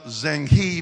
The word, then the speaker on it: Zheng He: It's spelled Zheng He, but it's pronounced Zheng He.